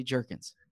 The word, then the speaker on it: Jurgens: There was questions on draft night about the pronunciation of his name, whether it's Jergens or Jergens. Ooh. It is definitively Jergens.